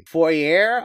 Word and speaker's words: Foere: Foyer, I believe it is. F-O-E-R-E. Foyer. is the name of the kingdom.